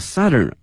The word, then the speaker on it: southern: Hangzhou is both the southern end of Beijing-Hangzhou Grand Canal，京杭大运河，杭州是它的这个最南端了，用了这个 end E-N-D，同时、啊、前面用这个 southern 而不用这个 south、啊、用它的这个南部的南端的形容词，同时这个发音、啊、大家已经注意到了是 Southern 小口型的 R 音，而不是这个 South 啊大口型的这个 R，